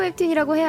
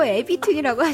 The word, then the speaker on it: 웹툰: 함경희님께서 라디오 웹툰이라고 해야 하는데 저희 어머니는 라디오 에비툰이라고 하셨어요.